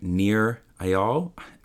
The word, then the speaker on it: Nir Eyal: It was an amazing concept I learned from this guy named Nir Eyal. I never pronounce his name properly, but N-I-R, his last name's E-Y-A-L, Nir Eyal.